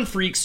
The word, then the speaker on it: Freecss: No, Gon, G-O-N, and his last name is F-R-E-E-C-S-S, which is pronounced Freecss, and it's actually purposely freaky.